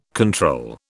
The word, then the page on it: control: British English